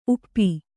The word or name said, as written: ಊಪು